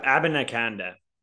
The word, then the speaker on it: Abanikanda: Ab Ab Abiniconda is how you say Ab- Abanikanda.